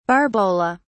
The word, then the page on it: barbola: British English